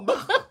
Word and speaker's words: Bach: I'm sorry, I'm laughing because you're saying Bach, and I studied German. I'm like, no, it's Bach. – Oh, Bach.